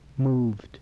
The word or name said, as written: moved